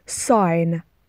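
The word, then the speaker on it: sign: توجه کنید به تلفظش ساین ساین ساین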